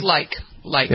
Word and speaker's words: Brooks Laich: Brooks Laich.